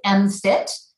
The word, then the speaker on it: MMFT: You've got to have an acronym when you work with the military, and we pronounce it MFIT because, you know, there's a parallel between physical fitness and mind fitness. And MFIT was to kind of capture we're working on mind fitness, which is why we went with that name.